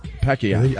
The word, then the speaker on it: Pacquiao: Pacquiao. – I think LeVar's right. – That's how they say it. Pacquiao.